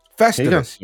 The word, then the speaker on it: Festivus: Festivus, you mean? Festivus. – I call it Festivius. I'd like to say it like that. I know there's no other I, but I like to it.